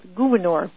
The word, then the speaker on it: Gouverneur: And it's spelled gouverneur, which is how I pronounce it, but there is discussion about whether or not that's really the way to say it.